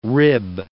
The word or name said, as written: rib